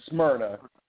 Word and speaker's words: Smyrna: You have the Atlanta Fibroid Center in Samara. Did I pronounce it right? The Samara location? – Georgia. We're in West Atlanta in Smyrna.